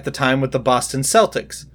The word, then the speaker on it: Celtics: Like, I mean, this team is the Celtics. They are the only thing that is ever properly referred to as Celtics. Everything else is Celtic.